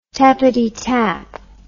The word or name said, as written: tappitytap